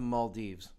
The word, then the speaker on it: Maldives: The Maldives. – Maldives.